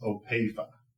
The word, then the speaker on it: Opeifa: My last name is pronounced Opeifa.